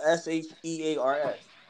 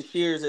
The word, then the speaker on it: Shears: So my last name is spelled Shears. – Mm-hmm. – But you can pronounce the Shears as in scissors, sharing some information.